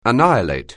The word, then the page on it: annihilate: anglais